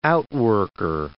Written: outworker

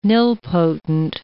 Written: nilpotent

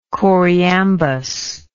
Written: choriambic

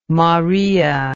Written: morea

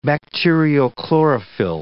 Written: bacteriochlorophyll

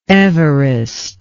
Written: everest

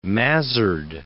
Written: mazzard

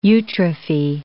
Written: eutrophy

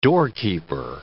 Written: doorkeeper

